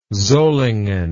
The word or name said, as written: Solingen